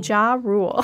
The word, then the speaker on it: Ja Rule: Ja Rule.